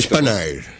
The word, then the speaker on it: Spanair: ¿Cómo se pronuncia? ¿Spanair? – Spanair. – Spanair, Ryanair.